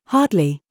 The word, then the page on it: DAD: British English